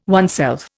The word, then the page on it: oneself: Indian